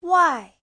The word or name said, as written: wai4